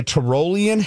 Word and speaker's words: Tyrolean: It's pronounced Tyrolean.